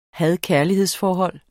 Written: had-kærligheds-forhold

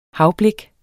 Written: havblik

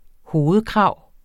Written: hovedkrav

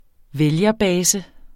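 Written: vælgerbase

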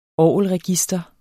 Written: orgelregister

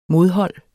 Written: modhold

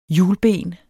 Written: hjulben

